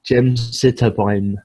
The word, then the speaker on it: gemcitabine: I don't even know how to pronounce that. So gemcitabine is the phonetic pronunciation of that.